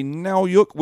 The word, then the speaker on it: Nowyuk: Jeffrey Nowyuk, which rhymes with cowbook, and Jen Slattery.